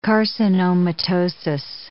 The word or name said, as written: carcinomatosis